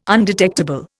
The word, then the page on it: undetectable: Indian